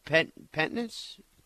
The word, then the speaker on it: penance: Remember, it's pen, not peen. Pen. – Pen. Like your friend Pensner. It's Pensnitz. – Penance, yeah. – Penance. – Yeah, okay. – So like you pay your penance. Like Yankees aren't winning pennants. – That's right, yes. Yeah. Spelled differently, but pronounced the same way.